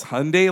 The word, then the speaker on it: Hyundai: When we got there and the reps were there, they're like, it's Hyundai like Sunday, not Hyundai like Sundai, okay?